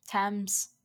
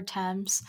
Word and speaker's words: Thames: Thames. I just looked up how to pronounce this. The River Thames.